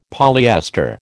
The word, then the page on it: polyester: American English